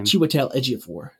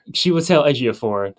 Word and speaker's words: Chiwetel Ejiofor: Chiwetel Ejiofor. – Chiwetel Ejiofor.